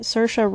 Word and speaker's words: Saoirse: So ever since I learned how to say it, Saoirse Ronan.